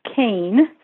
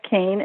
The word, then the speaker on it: Kean: Yeah, my website is called Surviving Death Kane. So it's the name of the book plus my last name, which is spelled K-E-A-N, Surviving Death Kane.